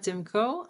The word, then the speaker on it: Tymko: My name is Ganna Tymko.